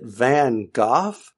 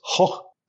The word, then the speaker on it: Van Gogh: And my good friend who is from the Netherlands says, ho. Really, that hard G is just not something that's really in the language. So, yeah, he's they call him Van Gogh.